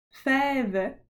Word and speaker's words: fève: There is an accent on the first E, a backwards accent, un accent grave. – Fève. Tomate is spelled T O M a-T-E.